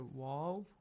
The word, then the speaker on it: و: Waw has to be pronounced correctly. Not a v, a v, a v sound we don't have. Like the letter V in English. We don't have that in Arabic.